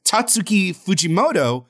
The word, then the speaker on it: Tatsuki Fujimoto: Tatsuki Fujimoto.